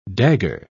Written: dagger

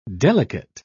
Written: delicate

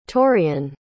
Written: torion

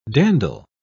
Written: dandle

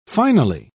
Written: finely

